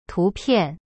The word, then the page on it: 图片: tu pian